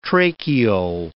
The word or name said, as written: tracheole